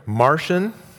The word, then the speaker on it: Marcion: So 100 years after Jesus, there was a man named Marcion, and that's spelled M-A-R-C-I-O-N, Marcion, of Sinope.